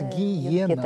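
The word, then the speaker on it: геенна: и греческий, получается «ге-ена». – Так произносим, ну, да, да, русском так, «ге-ена», вот у нас такое звучание.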